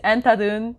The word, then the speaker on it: Andhadhun: So the pronunciation is Entadun. Endahun. Uh, i say like an end, a D at the end.